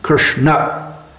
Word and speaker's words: Krishna: If we see a letter A without a line over it, it's pronounced a, Krishna.